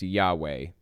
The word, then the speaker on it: Yahweh: It's supposed to be pronounced Yahweh, basically, with some accent marks in different places, but for the most part, it's supposed to be pronounced Yahweh.